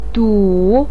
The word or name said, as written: du3